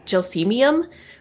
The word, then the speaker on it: Gelsemium: is Gelsemium sempervirens, which I say that like I took six years of Latin, because I did. Apparently, that's not how you pronounce it. I think it's actually Gelsemium, which I can't get myself to say, aside from telling the story.